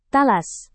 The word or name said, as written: talas